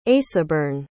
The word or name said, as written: aesoburne